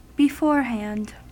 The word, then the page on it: beforehand: American English